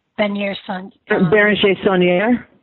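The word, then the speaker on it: Bérenger Saunière: Béranger Saunier.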